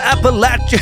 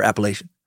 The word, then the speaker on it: Appalachian: And among many other Appalachian cryptids, they're what we're covering this next week. And yes, I do prefer that pronunciation over Appalachian.